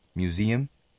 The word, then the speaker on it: museum: Este es el caso de museum, que se pronuncia museum, y de office, office.